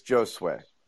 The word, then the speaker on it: Josue: Jose, come on, fix your Wi-Fi. Second thing. – First off, it's Josue.